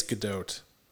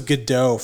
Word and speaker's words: Gadot: I think it's Gadot. – I'll never understand her name, but she's great. – I thought it was Gadot for the longest time, like silent T, but I don't think so.